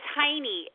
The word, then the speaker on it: Heiney: It's Heiney, H-E-I-N-E-Y. – So like the German pronunciation. – Correct.